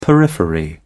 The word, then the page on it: periphery: British English